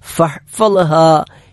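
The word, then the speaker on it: ഫഹ്ഫളഹ (فاحفظها): ഫഹ്ഫളഹ, അവിടെ ഫഹ് ഹാഇനാണ് സുക്കൂൻ, തൊണ്ടയുടെ മധ്യഭാഗത്ത് നിന്നാണ് ഹാ പുറപ്പെടേണ്ടത്. ഫഹ്ഫളഹ, ഫഹ്ഫൽ എന്നതിൽ ലാ നമ്മുടെ നാവിന്റെ മുൻഭാഗം മുൻപല്ലുകളിൽ സ്പർശിച്ചുകൊണ്ട് ഉച്ചരിക്കേണ്ട വാ ആണ്. ഫഹ്ഫളഹ ഫഹ്ഫളഹ.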